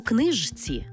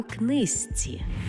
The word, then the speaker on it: книжці: Пишемо «жці», а вимовляємо «зці». Наприклад, не «подружці», а «подружці». Не «знижці», а «знизці». І не «у книжці», а «у книзці».